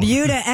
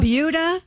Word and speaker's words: Buda: It's called the Buda. – Buda. – Sounds like Peter Wolf. – It's spelled B-U-D-A, and I looked it up. It is pronounced like beauty.